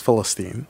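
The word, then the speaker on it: Philistine (فلسطين): And the Arabic pronunciation for Palestine is Philistine.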